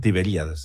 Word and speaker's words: Tiberíades: Fue descubierta Magdala, una ciudad bíblica al borde del mar de Galilea o lago de Tiberíades, así se pronuncia, ¿verdad? – Tiberíades, sí.